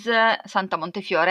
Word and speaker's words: Santa Montefiore: So this book by Santa Montefiore is Santa Montefiore. I have to pronounce it in Italian because it's Italian.